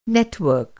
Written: नेटवर्क

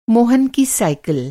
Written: साइकिल

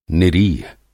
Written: निरीह

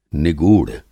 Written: निगूढ़

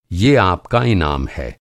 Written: इनाम

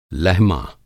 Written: लहमा

